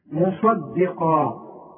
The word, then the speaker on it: مصدقا: مصدقا خد بالك في عندك صاد عامله زي الجبل وقاف عامله زي الجبل وبينهم دال رؤياء فالدال دي هتطلع منك ضاد اذا ما خدتش بالك من نطقها